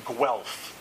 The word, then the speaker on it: Guelph: It's a city pronounced Guelph, G-U-E-L-P-H.